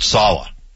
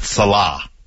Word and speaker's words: Saleh: Like the defense coordinator of the San Francisco 49ers is Robert Saleh, not Salah.